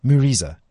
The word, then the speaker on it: Mureza: So just to be clear, the Muriza, how do you say it? Muriza. – Muriza. – Muriza. Muriza. Yeah, Muriza. Yeah. M-U-R-E-Z-A.